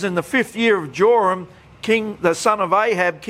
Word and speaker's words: Joram: So you can see these two with the same name both reigned together pretty much at a similar time, and their names are pronounced Joram.